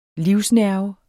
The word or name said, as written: livsnerve